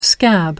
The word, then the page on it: scab: American English